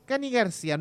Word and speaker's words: Cani García: Tenemos para el 9 de mayo, sábado, corríjame usted, se escribe Cani García, ¿está bien dicho? – Cani García